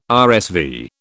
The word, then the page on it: RSV: British English